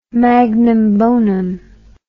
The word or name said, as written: magnum bonum